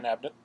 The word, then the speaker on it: Abnett: So it is by author Dan Abnett. Is that how you say it? – Dan Abnett, yeah, one of the best authors.